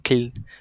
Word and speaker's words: Kiel: Kiel Kiel xong rồi Kiel tên là Kin đọc là Kin ở ờ,